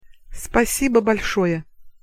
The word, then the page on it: спасибо большое: Russian